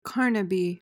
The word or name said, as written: carnaby